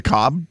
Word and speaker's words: Kotb: I always thought Hoda's last name was just Cobb. Is that wrong? – Hoda Cobb. – Mm. – Is that it? – I don't know. I just thought, well, how do you spell it? – K-O-T-B. Hoda Kotb. – I think it is Kotb.